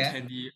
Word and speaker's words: Kandy: And I went to Kandy. I'm not sure if I'm pronouncing it right, but it's spelled Kandy. – Yeah, you actually are spot on.